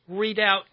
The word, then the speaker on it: Redoubt: Anyway, I got that soundbite you just heard about why we are a representative republic off of Readout News. That's spelled R-E-D-O-U-B-T News.